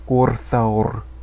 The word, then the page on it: Gorthaur: Sindarin